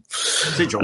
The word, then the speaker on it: sigil: Sigils. – I don't know why that was so hard for me to read. – Because you've been watching Matt Mercer a lot. It's pronounced sigil.